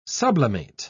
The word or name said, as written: sublimate